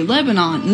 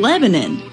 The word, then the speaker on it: Lebanon: Oh, you know, like the country, Lebanon. No. It's goddamn Lebanon.